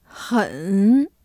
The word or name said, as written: 很